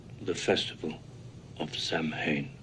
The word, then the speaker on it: Samhain: It's pronounced Samhain. – Samhain, almost like S-A-W-E-N. So that M is it's a soft M, and that's common in that tongue.